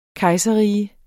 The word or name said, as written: kejserrige